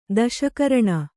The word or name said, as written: ದಶಕರಣ